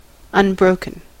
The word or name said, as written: unbroken